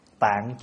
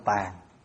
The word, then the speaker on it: Tạng: Địa là dày chắc, tạng chứa đủ. Tạng có khi đọc là tàng.